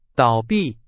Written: 倒闭

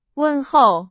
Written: 问候

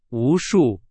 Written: 无数

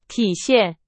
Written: 体现

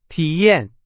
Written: 体验

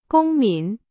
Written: 公民

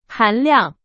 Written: 含量

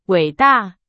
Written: 伟大

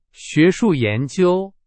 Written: 学术

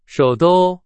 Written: 首都

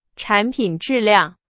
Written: 质量

